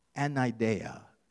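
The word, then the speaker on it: anideia (ἀναίδεια): In the New Testament Greek, persistence is anideia, is how it's said. In the prefix of this word, anideia, an, negates the related word to this, eidos.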